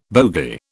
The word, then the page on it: bogie: British English